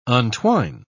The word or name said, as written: untwine